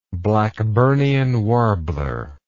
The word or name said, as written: Blackburnian warbler